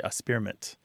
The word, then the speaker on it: experiment: So some people kind of skip the K sound; they say a spearmint, which is kind of more of a childish pronunciation.